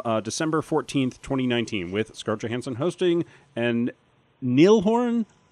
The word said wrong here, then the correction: Niall: Niall? – I looked it up. It's pronounced not like Nile. But he spells it weird. – Yeah, it's N-I-A-L-L.